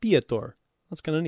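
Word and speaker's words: Piotr: Pieter. Okay, I never knew how to pronounce that, because it's P-I-O-T-R.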